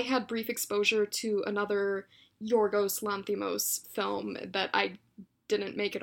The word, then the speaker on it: Yorgos Lanthimos: It's this little movie called The Lobster, directed by Yorgos Lanthimos. Correct me if I'm wrong on the pronunciation. – Yeah, that's right.